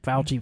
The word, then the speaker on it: Fauci: Fauci, from who is the like national infectious disease guy or whatever.